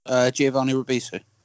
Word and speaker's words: Giovanni Ribisi: Giovanni Ribisi.